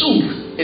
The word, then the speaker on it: suk: The word anoint in Deuteronomy chapter 28, verse 40 is the word suk. Everybody say suk. – Say suk. It's spelled S-U-K. But sometimes they also spell it S-O-O-K.